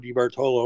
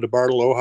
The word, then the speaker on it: DeBartolo: And IMDb, and they're all under my name, just Joe DeBartolo or Joe DeBartolo, however you want to pronounce it. I'll answer to both.